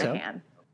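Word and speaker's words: Moynahan: Um, this movie stars Bridget Moynihan. Did I say that right? Moynihan? – Okay. Monahan. – Oh, Monahan.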